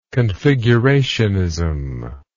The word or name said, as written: configurationism